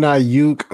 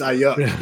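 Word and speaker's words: Aiyuk: Brandon Ayuk, of course, he had the disappointing Sunday. – Ayuk. – Oh, I was about to say, damn, right. I said his name wrong. But you did. It's Ayuk.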